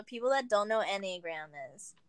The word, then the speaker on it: Enneagram: So is it Enneagrams or any, how do you say it? – It's Enneagram.